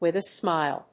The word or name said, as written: with a smile